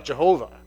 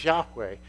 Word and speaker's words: Yahweh: it comes out Jehovah. But that's not how to say it. It's Yahweh.